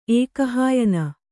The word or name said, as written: ಏಕಹಾಯನಿ